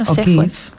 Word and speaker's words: O'Keeffe: Las flores porque las vamos a relacionar con una artista que es Georgia O'Keeffe. ¿Lo he dicho bien? – O'Keeffe.